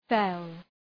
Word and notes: fell: fel

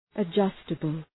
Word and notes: adjustable: ə’dʒʌstəbəl

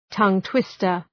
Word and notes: tongue-twister: tʌŋ’twıstər

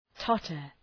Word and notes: totter: ‘tɒtər

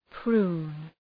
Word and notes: prune: pru:n

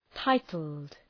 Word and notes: titled: ‘taıtəld

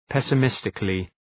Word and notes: pessimistically: ,pesə’mıstıklı

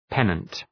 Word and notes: pennant: ‘penənt

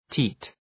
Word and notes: teat: ti:t

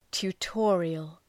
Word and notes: tutorial: tu:’tɔ:rıəl

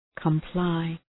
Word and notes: comply: {kəm’plaı}

